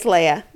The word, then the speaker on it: Leah: Leah, luckily. Leah. Wrong. – And I wrote it down. – Leah. Dang it. Leah. Okay, I'm going to say it right from now on. It's Leah. Okay.